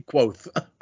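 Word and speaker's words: Kvothe: Supposed to be Kvoth.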